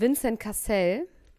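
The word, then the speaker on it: Vincent Cassel: Ich sehe hier gerade ein Hochzeitsfoto von Vincent Cassell.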